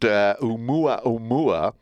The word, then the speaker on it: Oumuamua: It is pronounced Oumuamua, which is Hawaiian for scout.